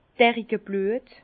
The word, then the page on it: Derickeblüet: Bas-Rhin; Herrlisheim